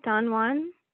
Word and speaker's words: Don Juan: Don Juan? – Yeah.